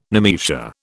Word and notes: nemesia: British English

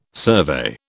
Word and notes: survey: British English

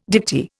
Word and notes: dicty: Indian

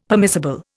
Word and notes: permissible: Indian

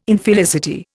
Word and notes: Infelicity: Indian

